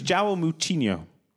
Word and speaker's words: João Moutinho: No, it's João Moutinho. That's how you say it.